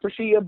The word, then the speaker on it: Preashea: I'm everywhere, and it's the same name on all social media platforms, and it's Prashea, P-R-E-A-S-H-E-A. It's pronounced Prashea, but it's spelled Prashea.